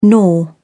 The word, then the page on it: nor: British English